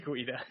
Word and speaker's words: Guida: Clay Guida.